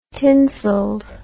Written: tinseled

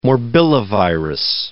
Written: morbillivirus

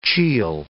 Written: chiel

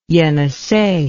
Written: Yenisei

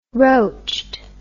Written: roached

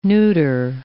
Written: neuter

